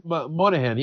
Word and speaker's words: Moynihan: Yeah, and then Bobby Moynihan. I'm just butchering that name. – Moynihan, yeah, he's a stand-up comedian.